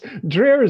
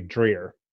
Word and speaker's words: Dreher: First, Rod, did I pronounce your name right? – It's close enough. – Close enough? How do you say it? – It's Dreher is actually how it's pronounced in German. Okay. But here in South Louisiana, we say Dreher.